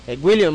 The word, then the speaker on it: Gwyllion: William, ma, el nombre se escribe G-W-Y-L-L-I-O-N.